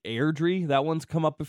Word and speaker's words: Airdrie: It's Airdrie. – It's Airdrie.